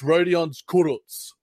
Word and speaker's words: Rodion Kurucs: And for all the NBA beat writers, Bill Simmons, everyone else, it's Rodion Kurucs.